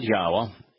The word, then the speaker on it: Yahweh: And Yahweh, that is God's name, that is how it is pronounced, Yod-Heh-Wah-Heh, and Yahweh, descended, Yarad, came down, lowered himself, diminished himself.